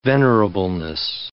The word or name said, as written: venerableness